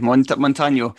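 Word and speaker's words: Montaño: So I went for Montano. Did you? Is that how you pronounced it? – Yeah, because he's got a wee. I don't know what they call it, but a wee accent above the N.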